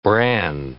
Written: bran

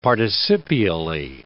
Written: participially

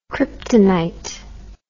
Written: kryptonite

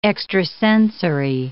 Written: extrasensory

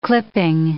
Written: clipping

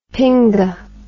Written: pinga

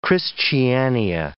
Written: christiania

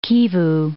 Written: Kivu

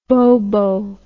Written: bobo

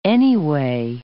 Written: anyway

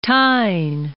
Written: Tyne

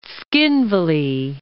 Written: Tskhinvali

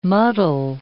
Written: muddle

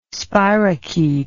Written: spirochete

